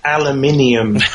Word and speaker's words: aluminium: I believe you pronounce that differently over your way. – Yeah, we end up dropping a couple letters out of the middle of that one. We just call it aluminium.